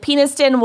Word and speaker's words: Peniston: I'm sure that's not how you pronounce it, but that is how you spell it. So. – I think you're nailing it, and that's exactly how Peniston. You pronounce it.